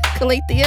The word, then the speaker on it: Calathea: You are lovely like Lala the Calathea. Is that how you say it? Calathea?